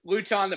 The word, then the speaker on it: Luton: Yeah, you called him Luton the futon. I think it's pronounced Luton. – I like Luton more. – Rhymes with gluten.